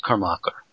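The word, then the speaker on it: Kormákur: No, because the accent is on the A, so it's Kormáker.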